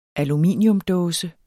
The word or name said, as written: aluminiumsdåse